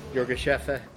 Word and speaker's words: Yirgacheffe: Is this Yirgacheffe? Or how do you say that? – Yirgacheffe.